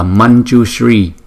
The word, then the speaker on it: Manjushri: đọc là Manjushri vì chữ J thuộc vùng Palatal nên đổi chữ Anuswara M có dấu chấm thành nhờ.